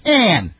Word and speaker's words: Ayn: It's Ayn. Her name's Ayn.